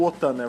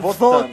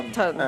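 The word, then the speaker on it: Wotan: Inclusive, a pronúncia não é o OTAN, é VOTAN. VOTAN. É. Né? Tipo, da Alemanha.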